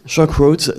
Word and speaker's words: Shock Roads: Shock Roads, qui est sorti, je l'ai prononcé correctement ? – Ouais. – Shock Roads, donc, qui est sorti là tout récemment.